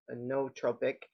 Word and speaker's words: nootropic: But I do take a nootropic, or how'd you pronounce it? – Nootropic.